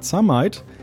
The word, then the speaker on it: Zameit: Wie genau das funktioniert, das erklärt uns heute Professor Dr. Alexander Zameit ähm, Juniorprofessor für Diamant-Kohlenstoff-basierte optische Systeme an der Universität Jena. Ich hoffe, ich habe das richtig ausgesprochen. – Wunderbar, perfekt, genau.